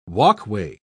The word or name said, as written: walkway